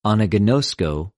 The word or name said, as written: ἀναγινωσκω